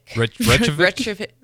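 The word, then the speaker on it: Reykjavík: Reykjavik? – Reykjavik. – I'm saying it right. I don't want to butcher it. I know that one's, I feel like one that Americans probably say wrong commonly.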